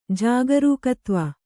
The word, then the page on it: ಜಾಗರೂಕತ್ವ: Kannada